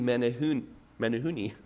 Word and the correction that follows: Menuhin: After the concert, he tracked down Menuhin, is how it's pronounced, and he said, I would give my life to play like that.